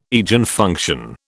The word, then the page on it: Eigenfunction: British English